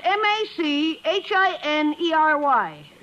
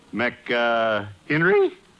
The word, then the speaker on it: machinery: Now, how do you pronounce M A C H I N E R Y? – M A C. Mac, uh, Henry? – No, machinery. – Machinery?